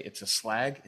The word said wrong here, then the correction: slog: It's not a slag. – That's not a slag. – It's a slog. A slog? – I don't think the British say. I think we say slog.